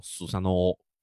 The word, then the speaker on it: スサノオ: 哎，速斩明尊跟须佐之男在日语里的读法都读作スサノオ。